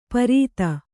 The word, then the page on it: ಪರೀತ: Kannada